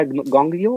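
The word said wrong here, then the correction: Nyong'o: o algo así, no sé cómo se pronuncia. Lupita. – ah, Lupita Nyongo. Este...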